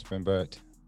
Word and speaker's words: Rembert: Yeah, it's Rembert.